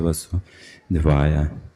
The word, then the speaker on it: वासुदेवाय: Ударение делается «васудевая». Уже другое ударение, как другое значение имеет. А здесь у нас не точный акцент, неуловимый акцент, я бы сказал. Неопределенное ударение. А ударение делается на последнем «васудевая».